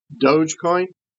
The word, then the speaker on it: Dogecoin: Look and see what happened to, and I'm going to mispronounce it, but Dogecoin? – Yeah, no, I think you got it.